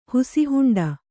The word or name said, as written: ಹುಸಿಹುಂಡ